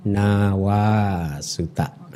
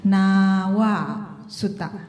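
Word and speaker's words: Nāvā Sutta: Nawa Suta. Seseorang hendaknya di, menghormatinya. Di, diulangin dulu, bukan nawa. Kalau a berarti pendek. Ini bacanya a-nya panjang. Nawa Suta. Oke. – Okay. Nawa Suta.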